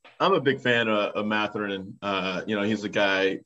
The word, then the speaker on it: Mathurin: Mathurin of Indiana. What do you think of Mathurin? Am I saying that right? – Mathurin.